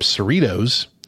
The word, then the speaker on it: Cerritos: Star Trek Lower Decks is an animated adult series that follows the low-ranking support crew of the starship Cerritos, rhymes with Fritos, or Doritos, in the 24th century.